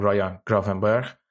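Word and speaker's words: Ryan Gravenberch: وقتی از این مالیشون میگن خارجه خارجه دیگه جدا از خود این آقای که اسمش فراموش کردم رایان گرافنبرگ تلفظ درستشه در هلندی این مراکشی مزروی رو میخواستیم بگیرین که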